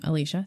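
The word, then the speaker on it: Alycia: Alicia. That's my name. Alicia. Thank you. And, spelled with a Y, not an I.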